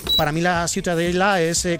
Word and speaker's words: Ciutadella: ¿cómo se pronuncia? – La Ciutadella. – Ciutadella. Bueno, pues Ciudadela también en castellano. – Ciudadela, sí. Bueno, pues eso, ha quedado clarísimo. Pero, ¿para qué se meten? – ¿Tú cómo lo pronuncias? – Ciutadella. – Muy bien.